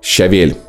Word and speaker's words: щавель: Щавель. Я смотрел. Нет, щавель. – Щавель. Ударение на вторую. Ну, ну, в том смысле, на Е.